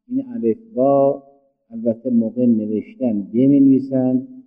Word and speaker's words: با: ولی وقتی خواستیم تلفظ بکنیم نمیگیم جه، بگیم جیم. نمیگیم به، میگیم با.